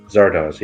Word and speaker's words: Zardoz: Has anyone seen the Sean Connery movie Zardoz? – I haven't. – Am I pronouncing it right? Zardoz, yeah. – How do you spell that? – Z-A-R-D-O-Z.